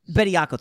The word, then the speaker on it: Bediako: Kaluma on Miller, you got the seven footer against uh, Betty Yan- uh, Yanko. I hope I said his name right. And then the guard Betty matchups, Yanko. like yep. – Bediako, thanks. The, the, the, Creighton guards have played a tremendous.